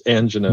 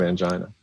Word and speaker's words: angina: Doctors right. always say tinnitus. Civilians tend to say tinnitus. – Exactly. – And doctors have it's like a guild thing. They have their own pronunciations, angina instead of angina.